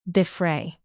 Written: defray

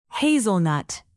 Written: hazelnut

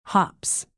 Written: hops

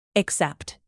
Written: except

